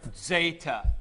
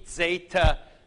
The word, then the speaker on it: Zeta: Zeta is pronounced with a D, Z, Zeta, Z. As a matter of fact, in Athens, at classical Greek times, they probably didn't pronounce it that way. They probably pronounced it just the opposite, Z-D, Z-D, like this. But it has kind of turned around over the years, and it's like our word adds.